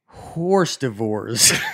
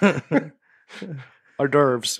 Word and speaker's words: hors d'oeuvres: I pronounced it as ours devours. And probably, I don't know. Probably got ridiculed by my friends. I'm curious how you are pronouncing it right now. I said horse divorce.